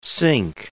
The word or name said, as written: sink